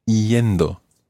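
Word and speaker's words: iendo: Aprendiendo, coming from aprender, and notice with the ER verb, we've taken off the ER and added yendo, that's I-E-N-D-O, yendo.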